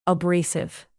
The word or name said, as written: abrasive